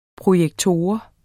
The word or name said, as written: projektor